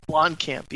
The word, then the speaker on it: Blomkamp: I saw that, Bloody Disgusting reported that Alien, Alien 5, I always mispronounce this guy's last name, Blockman? – Blomkamp. – Blomkamp, yeah.